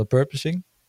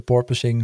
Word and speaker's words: porpoising: En ehm, goed, ik heb wat berichten gelezen dat eh, Ferrari heeft natuurlijk nog steeds best wel last van de purposing. De porpoising, hoe je het ook maar wil uitspreken.